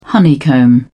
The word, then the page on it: honeycomb: British English